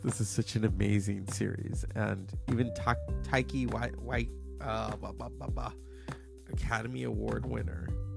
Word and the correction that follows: Taika Waititi: I never say his name right. In the first season, Taika Waititi.